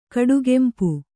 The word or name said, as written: ಕಡುಗಂಪು